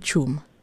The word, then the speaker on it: Chum: I said Chum. – Yes. – Yay! Okay. We have to check because at first I was like, wait, is it Chuma? Is it, you know, I had another pronunciation in my mind, but I was like, I will check first if I got it right.